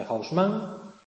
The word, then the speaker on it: Haussmann: Ya digo, en este sentido lo podremos comparar con el París de Haussmann, o de Haussmann, pronunciado a la francesa, o con el Vigo del plan Palacios.